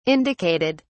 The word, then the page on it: indicated: British English